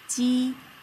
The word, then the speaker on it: j: The first set is ji, qi, xi. The letter J is pronounced as ji, like the beginning of jeans.